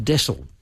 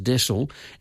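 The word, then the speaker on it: deasil: And someone got in touch with me, and they'd come across this quote that mentioned widdershins, and it's pronounced desil. It's D-E-A-S-I-L, but it's pronounced desil, and it means clockwise.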